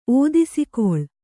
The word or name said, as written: ಓದಿಸಿಕೊಳ್